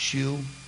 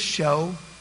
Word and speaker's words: shew: Can I just, when you're reading the Old Testament and you come along S-H-E-W, it's not shoe. Just, it's show. It was just the old English way that they wrote that word show. And it just pronounce it show, just like, amen. Just like if it had an O in there.